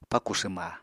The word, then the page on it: pakù swma: Apatani